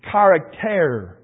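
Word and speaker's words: charakter (χαρακτήρ): For instance, there's the word, I'll try to pronounce it in the Greek, charakter is the word. And it's a X-A-R-A-K-T-A-R.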